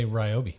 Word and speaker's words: Ryobi: I say Ryobi. – There you go. – Of course you do. – Good man. – Because you're a consumer. – No, because it's like my name.